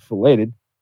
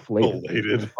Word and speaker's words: filleted: filleted. How do you say that? It's not filleted. – Filleted. Not filleted. – The T's just completely silent.